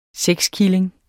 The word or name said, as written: sexkilling